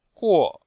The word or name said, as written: guo4